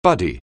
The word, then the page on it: buddy: anglais